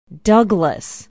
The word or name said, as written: douglas